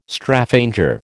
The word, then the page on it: Straphanger: American English